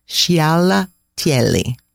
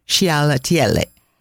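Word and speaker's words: scialatielli: It's a bit of a tongue twister to say. So if you just say it faster, it might sound better. Sciallatielli. Sciallatielli.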